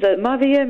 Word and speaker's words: merveilleux: Now, the next one, again, I'm going to mispronounce it. I did A-level French, but obviously it's down the drain, all of this. Okay. Vanilla Merveilleux.